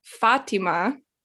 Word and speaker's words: Fátima: So for example, Fatima, that's kind of how you would say it in Spanish too.